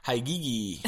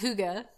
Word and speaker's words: hygge: And she came across something called Hygiege. – That's what it looks like. It's actually pronounced hygge, which is a Danish word.